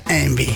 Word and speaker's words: Yngwie: Pues se pronuncia Envy.